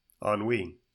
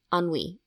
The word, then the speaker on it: ennui: How do you say E-N-N-U-I? – Ennui. – Ennui. I always say N-U-I. – Mm-mm. – Yeah. Ennui. – It's French.